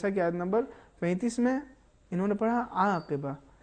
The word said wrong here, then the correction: عاقبہ: صحیح ہے عاقبہ, حالانکہ انہوں نے بعد والی آیت میں اعلیٰ ہداہم اس میں آئین کا صحیح تلفظ کیا ہے.